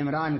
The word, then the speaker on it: عمران: قرآن اسی کا تلفظ عمران کرتا ہے.